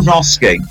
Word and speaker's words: Aronofsky: That was Darren Aaron Ofsky. You can't say it like that. I'm not uh, allowing Darren that. Aronofsky. Darren Aronofsky. There you go.